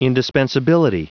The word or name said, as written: indispensability